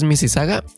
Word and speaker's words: Mississauga: Si la buscan, se escribe Mississauga. Así es que, pero la pronunciación, como muchas palabras en el inglés, ya saben, se escribe así, pero se dice de otra forma. Así es que es Mississauga y esta está en la ciudad, bueno, en la provincia de Ontario.